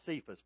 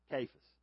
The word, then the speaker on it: Cephas: We look at it and we want to call it Cephas, but it's actually pronounced Cephas.